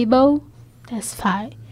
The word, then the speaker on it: Abel Tesfaye: Só que o, o jeito de pronunciar é Abel Tesfaye.